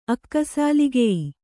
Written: ಅಕ್ಕಸಾಲಿಗೆಯಿ

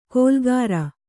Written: ಗೋಲಾಕಾರ

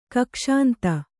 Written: ಕಕ್ಷಾಂತ

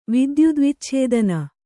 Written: ವಿದ್ಯುದ್ವಿಚ್ಛೇದನ

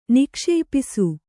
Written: ನಿಕ್ಷೇಪಿಸು

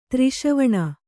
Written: ತ್ರಿಷವಣ

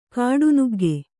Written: ಕಾಡುನುಗ್ಗೆ

